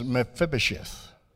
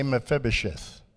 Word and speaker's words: Mephibosheth: The name is Mephibosheth. Everybody say Mephibosheth.